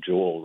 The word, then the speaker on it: Joel: I mean, I don't think it's a coincidence that Joel rhymes with soul.